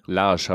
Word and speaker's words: Lage: Lage, habe ich gelernt. – Lage. Ach so, ja, das E ist ja stumm. Das ist ja, hat ja kein Akzent, stimmt.